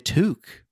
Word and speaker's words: toque: And also a jacket on and a toque. I'm wearing my toque. The man's wearing a toque, Dan. – Dan, are we saying that right? Toque? Yeah. Yeah, you got it.